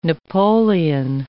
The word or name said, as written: Napoleon